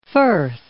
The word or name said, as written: firth